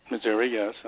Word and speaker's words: Missouri: That's K-R-I-S-T-I-N, L. Hoffman. Again, from Missouri. How do I say it? Missouri? – Missouri, yes. – Right. Oh, I said it right.